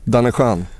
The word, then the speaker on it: Danne Schön: Eller uttalar jag det rött? rött, rätt? – Danne Schön? Ja. ja, det är ju helt rätt. Mm.